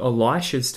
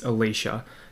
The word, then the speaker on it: Elisha: Some people pronounce this Elisha, just to make it a little bit clearer to compare it to Elijah, but I'm just going to call it Elisha, just for argument's sake.